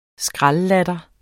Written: skraldlatter

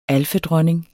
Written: alfedronning

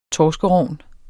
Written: torskerogn

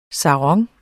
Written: sarong

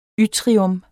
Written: yttrium

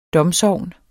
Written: domsogn

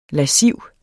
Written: lasciv

